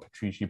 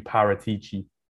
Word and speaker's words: Paratici: Paratici, I mispronounced the name. Not Petrici, Paratici.